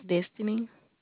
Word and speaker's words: Destiny: My name is Destiny.